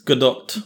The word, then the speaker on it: Gadot: Well, you got to pronounce that T. – Yeah, but I mean, it sounds. – That sounds more like dot than Gadot. It's Gadot.